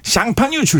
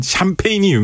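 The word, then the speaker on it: Champagne: 네, 아페르라는 이 사람이 프랑스에서도 샹파뉴 출신이거든요. 샹파뉴 하면 우리가 유명한 게 뭐가 있을까요? 샹파뉴 하면 샴페인이 유명하죠. 샴페인이 이제 영어식 발음이고 프랑스에서는 샴페인을 샹파뉴라고 하죠.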